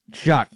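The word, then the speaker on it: Shough: is it Shock or Shaw? I always forget. – Tyler pronunciation. Shock.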